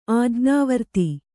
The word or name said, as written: ಆಜ್ಞಾವರ್ತಿ